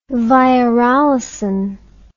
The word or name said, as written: virolysin